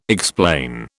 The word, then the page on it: explain: British English